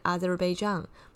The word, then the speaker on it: Azerbaijan: 好，那这个亚塞拜然呢，我们上礼拜说过，他其实他的发音比较接近 Azerbaijan，那所以呢，这个大陆这边它的翻译的方式是阿塞拜疆哦。